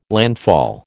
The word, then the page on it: landfall: American English